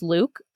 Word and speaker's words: Luc: She was the daughter of Suzanne and Jean-Pierre Bloch, and she had a half-brother, Luc, spelled L-U-C, but pronounced Luke,